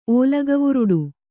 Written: ಓಲಗವುರುಡು

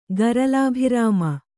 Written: ಗರಲಾಭಿರಾಮ

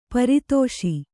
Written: ಪರದೇಶಿ

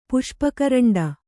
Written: ಪುಷ್ಪಕರಂಡ

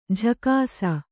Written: ಜಾಗಿಸು

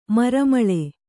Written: ಮರಮಳೆ

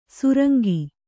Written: ಸುರಂಗ

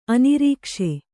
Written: ಆನೆರಕ್ಕಸ

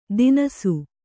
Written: ತಿನಸು

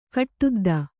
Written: ಗಡದ್ದು

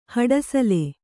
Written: ಹಡಸಲೆ

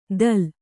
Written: ದಲ್

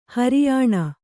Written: ಹೆರೆಯೆಣ್ಣೆ

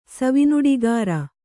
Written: ಸವಿನುಡಿಗಾರ

